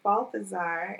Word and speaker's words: Balthazar: I'm so grateful to have had you, an expert in sleep research, at Dr. Monique Balthazar.